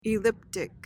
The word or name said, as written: elliptic